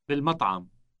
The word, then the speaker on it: بالمطعم: بالمطعم. بالمطعم. – Note that the word starts with a B sound that stands for the preposition at. It is followed by an L sound, which is the definite article.